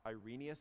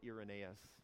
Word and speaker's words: Irenaeus: Irenaeus or Irenaeus, you can choose how you want to pronounce this.